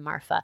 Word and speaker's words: Marfa: If y'all have more information on Marfa, any of you are like locals or Texas locals. – Is it with an F? Am I saying it right? – Yeah. – Okay. – M-A-R-F-A.